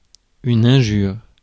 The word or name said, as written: offense